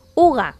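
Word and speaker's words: hygge: Bien, y llegamos a la última palabra, último palabra raro de hoy, que se escribe hije, pero ya hemos dicho que se pronunciaba uga, y ahora veremos por qué.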